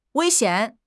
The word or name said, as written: wei1 xian3